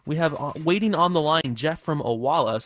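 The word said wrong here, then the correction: Olalla: So am I saying that right? Owala? Or is it? – Olala. – Olala. – Ah, good. Good to hear.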